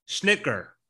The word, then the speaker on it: Schnicker: So, Thursday, we've got Dr. Gary Schnicker. I think is how you say his last name. He said it's like saying Snickers, but with like a shh at the front, schnicker.